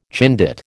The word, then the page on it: Chindit: American English